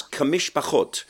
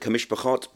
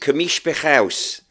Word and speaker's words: Kamishpachot: And my teacher, rest in peace, Louis Rosenberg, Baruch Dayan Emet, he said the way I had to pronounce Kamishpachot in Ivrit, modern Ivrit, Kamishpachot, but in Birmingham we pronounce it Kamishpachaus.